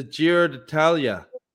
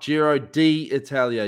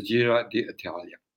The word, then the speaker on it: Giro d'Italia: I love you, but man, it's the Giro d'Italia, not Giro d'Italia. You're doing her head in. – You're dead right. I've got to put more effort into my pronunciation. Pronunciation. You're dead right. And it is the Giro d'Italia. Just the D.